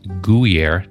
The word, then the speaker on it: Guyer: Hey, your host, talking today with Trent Guyer.